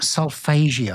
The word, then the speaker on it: solfeggio: So, but tell me about solfeggio.